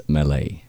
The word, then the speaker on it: melee: So, breaking news, I just interneted how do you pronounce that word, and the internet says melee.